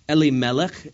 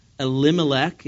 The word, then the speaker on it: Elimelech: Reading on in verse 2, the name of the man was Elimelech in Hebrew, or Elimelech in usual English pronunciation.